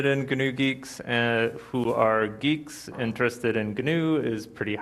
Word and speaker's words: Guix: I would like to present to you my latest infatuation, my crush on GNU geeks. I'll start off with, it's kind of awkward calling it GNU geeks, especially when you're talking about crushes, but I, for the longest time, pronounced it GUIX.